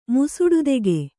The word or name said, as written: ಮುಸುಡುದೆಗೆ